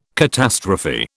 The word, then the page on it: catastrophe: British English